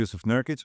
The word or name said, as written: Jusuf Nurkic